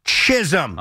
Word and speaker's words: Chisholm: Jazz Chisholm. – Okay. – I won't fail this one. Justin Shackle may struggle. – Oh, they're sweating. – John Sterling has struggled. – Jazz Chisholm.